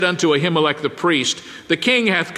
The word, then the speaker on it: Ahimelech: Then came David to Nob, to Ahimelech the priest. In fact, in the Hebrew, the word is pronounced Ahimelech, but we'll call it Ahimelech since that's what we're used to.